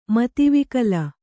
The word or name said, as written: ಮತಿವಿಕಲೆ